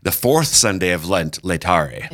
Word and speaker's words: Laetare: Laetare.